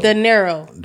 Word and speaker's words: De Niro: You didn't know how to say his last name? It's Robert. De Niro. – De Niro. – You did not say that. You said De Niro.